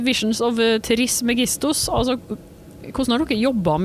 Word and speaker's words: Trismegistus: Visions of Trismegistus? Er det det du sier? Trismegistus? – Ja. – Tribestigos? – Trismegistus. Det er ingen av oss som er grekere nok til å uttale det korrekt uansett, antageligvis.